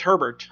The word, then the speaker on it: Herbert: Ducks, they've kind of lived and died with the performance of their quarterback or the availability of their quarterback, Justin, either Herbert or Hebert. I'm not sure how to pronounce. I think it's Herbert. – Herbert, okay.